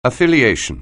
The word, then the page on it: affiliation: anglais